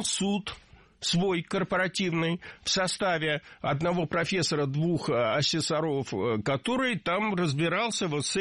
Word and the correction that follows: асессоров: Лучше сказать не так, не асессоров, а асессоров. В слове «асессор» ударение на втором слоге сохраняется во всех формах. Асессоры, асессоров, асессором. Современный асессор – это специалист, который оценивает качество работы алгоритмов, систем.